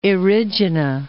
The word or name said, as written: Erigena